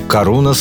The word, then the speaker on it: Karunos: Послушаем же «Карунас» с ударением на «у».